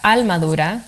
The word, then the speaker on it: Almadura: But in Puerto Rico, when you pronounce the R, you kind of drop it into an L a little bit. And so it turns into Almadura, which means like strong soul.